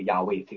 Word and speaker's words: 亚威: 但是现在圣经的研究学者就是说，大概这个发音呢是可以发音的原文可以发音成那个亚威这个字啊，但是他们虽然忌讳不发音啊，这个你说犹太文化跟中国文化，你看我们对我们受尊敬的人，特别是呢在威权封建的威权时代。